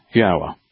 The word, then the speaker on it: Yahweh: Therefore, it is Yahweh.